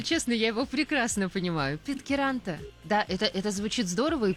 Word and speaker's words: Питкяранта: Мой родной город Питкяранта. – Еще раз. – Даже на поле чудес. Город Питкяранта. – Питкяранта.